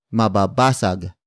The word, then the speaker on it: mababasag: Hindi is spelled as H-I-N-D-I. It is a negation word, which closely translates to no or not. The next word is mababasag. Mababasag. Mababasag. Mababasag is spelled as M-A-B-A-B-A-S-A-G. It means will break.